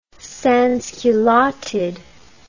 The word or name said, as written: sansculottide